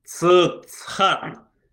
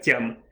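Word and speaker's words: Тян (ちゃん): «Цхан!» «Не!» — Аня засмеялась. «Это читается как Чан, а произносится как Тян.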